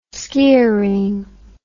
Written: skioring

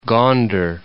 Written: Gonder

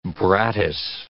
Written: brattice

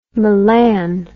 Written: melan

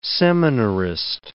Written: seminarist

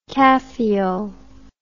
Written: caffeol

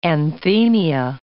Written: anthemia

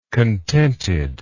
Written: contented

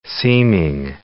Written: seeming

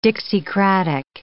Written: Dixiecratic

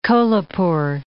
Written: Kolhapur

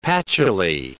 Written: patchouly

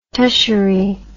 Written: tushery

